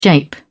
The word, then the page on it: jape: British English